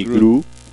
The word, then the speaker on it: Groot: Rocket Raccoon y Groot. – Sí. ¿Gru o Groot? – Groot. – Sí. – Groot. ¿Cómo se pronuncia? – Groot, ¿no? – Ellos son los que se llevan la película.